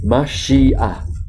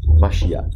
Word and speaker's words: Mashiach: It is pronounced as Mashiach, Mashiach.